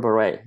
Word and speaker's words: Bere: Doru Bere, yes.